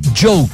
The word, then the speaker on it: Djo: Next up, we have The Struts, Coldplay, and here's Joe Carey's musical project, Joe, D-J-O, that's how you spell it, okay?